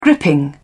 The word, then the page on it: gripping: British English